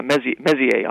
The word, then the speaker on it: Messier: how do you pronounce it Mercier objects? – Oh, uh, yeah, Messier, objects. Messier, yeah.